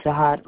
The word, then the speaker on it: Jahide: J-A-H-I-D-E. Last name, R-O-B-I-N-S-O-N. – How do you pronounce that? – Jahad Robinson.